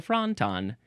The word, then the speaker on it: fronton: It's like a wonton, but it's a fronton. It's spelled F-R-O-N-T-O-N.